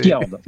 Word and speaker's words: Fjord: Genre, je sais pas trop comment ça se prononce, hein, c'est Fjord. – Fjord.